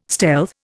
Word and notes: stealth: Indian

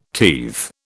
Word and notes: teethe: British English

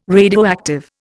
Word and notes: radioactive: Indian

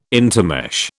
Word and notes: intermesh: British English

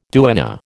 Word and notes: duenna: American English

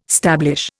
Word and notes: stablish: Indian

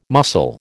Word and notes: muscle: American English

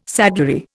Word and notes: saddlery: Indian

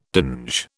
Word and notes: dinge: British English

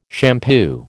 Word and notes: shampoo: American English

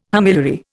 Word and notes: homiliary: Indian

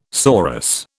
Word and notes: sorus: British English

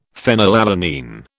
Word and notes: phenylalanine: British English

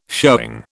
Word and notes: showing: British English

